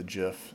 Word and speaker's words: GIF: The creator of the GIF says it's GIF, like the GIF peanut butter.